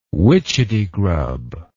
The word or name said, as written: witchetty grub